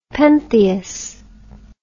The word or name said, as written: pentheus